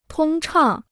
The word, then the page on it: 通畅: tōng chàng